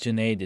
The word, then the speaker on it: Janae: So my name is Janae. It rhymes with lemonade.